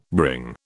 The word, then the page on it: bring: British English